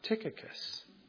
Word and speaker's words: Tychicus: But that you may know my affairs and how I am doing, Tychicus, and that's how you pronounce it, Tychicus. I had to look it up.